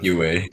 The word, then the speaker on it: Yueh: And then like it says, like, if they spell it on pinyin, which is basically phonetic spelling of Chinese characters, it's actually pronounced like Yueh.